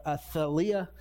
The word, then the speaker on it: Athaliah: Now Jehoram was fine in the very beginning, but he started a date and he married Alethea or Athaliah. That's how you pronounce her name.